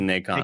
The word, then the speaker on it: Nacon: Hashtag, would you pronounce it Nason or Nacon? – This is Nacon. I can go with Nacon.